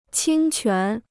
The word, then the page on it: 侵权: qīn quán